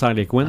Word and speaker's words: Harlequin: C'est que Harlequin en anglais, ça se prononce Harlequin.